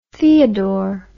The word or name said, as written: theodor